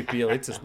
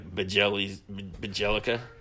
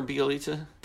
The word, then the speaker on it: Bjelica: Because I didn't even know how to say his name until like 2 years ago. Mike Gallagher taught me how to say his name. I used to be like Bajelica.